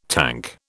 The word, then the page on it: tank: British English